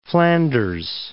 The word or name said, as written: Flanders